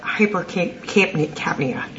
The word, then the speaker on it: hypercapnia: What about, doesn't hypercapnia, am I saying that right? – Correct.